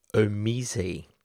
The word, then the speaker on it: Omise: OMG is the coin behind the company Omise. Now, apologies for the pronunciation, but it's spelled O-M-I-S-E, pronounced Omise.